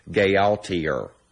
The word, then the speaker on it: Gaultier: Pronouncing that word makes me miss Aaron, who would have pronounced it, of course, Gaultier.